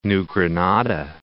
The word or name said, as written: New Granada